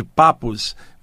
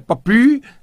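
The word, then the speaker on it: Papus: Um deles, o Papi, que todo mundo chama aqui no Brasil de papos, mas a pronúncia certa é papi, aquela expressão francesa,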